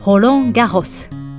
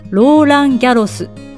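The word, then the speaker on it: Roland-Garros: ホロンギャホス。ホロンギャホス。日本ではローランギャロスと呼ばれますが、実際の発音はだいぶ違いますね先頭の文字は「エーフ」ですから気をつけましょ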